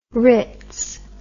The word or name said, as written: rits